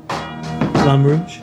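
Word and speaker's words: Flamme Rouge: I guess we'll be reviewing Flame Rogue. – Flame Rouge? – Yes, that one.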